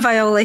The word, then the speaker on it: Veola: I said V-E-O-L-A because the French I is pronounced E.